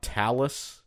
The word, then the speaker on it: talus: And you said you thought talus is a scientific bone word because it rhymes with Alice.